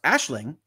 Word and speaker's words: Aisling: Aisling, name pronounced Ashling. Ashling, sorry.